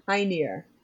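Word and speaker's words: Hœnir: it is Honir. The uh, oh. oh nope, it's pronounced Hynir. I wrote that down because I was going to say it wrong which is what I would do.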